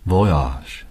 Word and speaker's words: voyage: Bon voyage. – Voyage. – Voyage. – Yeah. – Soft G. Voyage. – Bon, B-O-N, voyage. – Voyage. Auf Wiedersehen. – Auf Wiedersehen. – Auf Wiedersehen. Auf Wiedersehen. Auf Wiedersehen. Auf Wiedersehen. Auf Wiedersehen. Auf Wiedersehen. Auf Wiedersehen. Auf Wiedersehen. Auf Wiedersehen. Auf Wiedersehen. 好了，今天的张道真自学英语空中课堂就到这里。我是周敏，我们下期节目再会。 – See you guys soon. – Bon voyage.